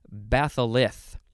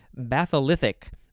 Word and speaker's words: batholith: Now we have batholith. I sound like I have a lisp. B-A-T-H-O-L-I-T-H. Batholith. It sounds like I'm trying to say the word bath.